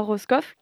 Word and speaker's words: Roscoff: On passe de la rue Cobalan, euh, dans la ville de Tolé à Roscoff, qui se dit Rosco en breton, puis euh, Wesson, qui se dit, il faut corriger ma prononciation, Eza en breton.